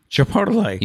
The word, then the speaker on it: Chipotle: Chipotle.